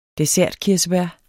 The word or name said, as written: dessertkirsebær